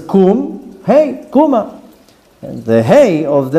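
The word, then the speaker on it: Kum (קום): Hainu, kum, get up.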